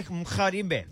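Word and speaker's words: Mgarimbe: Just say mkharimbe. Just like that. Mkharimbe. Yes, there we go.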